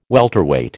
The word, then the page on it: welterweight: American English